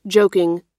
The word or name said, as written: joking